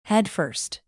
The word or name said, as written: headfirst